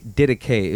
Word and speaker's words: Didache: It's gonna be Didache.